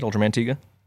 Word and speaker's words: Ultraman Tiga: Ultraman Tiga. That's how you say that, right? – That is how you say it, Ultraman Tiga.